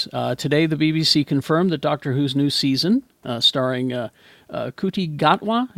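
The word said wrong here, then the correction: Ncuti Gatwa: is that how you say it? – The 15th Shuti Doctor. Gatwa. – Shuti Gatwa, thank you.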